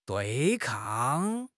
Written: 对抗

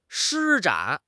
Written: 施展

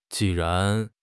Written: ji4 ran2

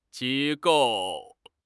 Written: 机构

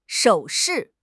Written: 首饰

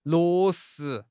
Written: 落实

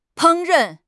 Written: 烹饪

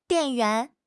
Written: 电源

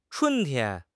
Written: chun1 tian1

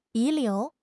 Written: 遗留

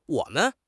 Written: wo3 men5

